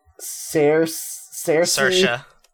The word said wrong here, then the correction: Saoirse: Ronan? Saoirse Ronan, uh, uh, who Louisa chose, so congratulations to Louisa.